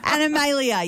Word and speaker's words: Animalia: I always pronounced it Animalia. – Yeah, that's it. Animalia, yes, that is how you say it.